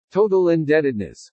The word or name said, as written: TOTAL INDEBTEDNESS